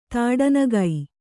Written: ತಾಡನಗೈ

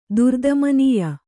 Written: ದುರ್ದಮನೀಯ

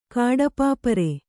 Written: ಕಾಡಪಾಪರೆ

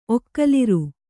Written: ಒಕ್ಕಲಿರು

